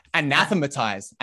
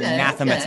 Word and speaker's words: anathematize: Anathematize. – Okay. – Anathematize.